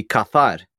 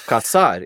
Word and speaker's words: cazar: But the word casar, C-A-S-A-R. Yeah. Or more appropriately, casarse is to get married. But if you change the S to a Z, in Spain it's pronounced differently. It'd be cazar. But in the Americas, it's cazar. It sounds the same, but it means to hunt.